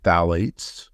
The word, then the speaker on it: phthalates: It's P-H-T-H-A-L-A-T-E-S. And when you pronounce it, ignore the first P-H. So just pretend like it's just T-H-A, so phthalates.